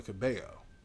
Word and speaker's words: Cabello: I feel like you said her last name wrong right there, but go ahead. – Cabello? It's two L's. – Okay. – Do you speak and spell Spanish now? – Do I what? – Do you speak and spell Spanish now? I mean, I know how to say her name.